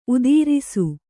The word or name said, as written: ಉತ್ತರಸ